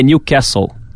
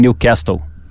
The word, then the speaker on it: Newcastle: Aliás, aprendi com o teacher Fábio Emerim também, que é Newcastle. Newcastle. Não Newcastle.